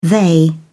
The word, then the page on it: they: British English